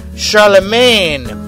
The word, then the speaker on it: Charlemagne: Uh, Sacre Chalaman, I think is the way they're pronouncing it. Thank you, Georgie. Kick over some more CDs. Uh, s- uh, I'll give you the American pronunciation. Sac- Sacre Charlemagne. Charlemagne.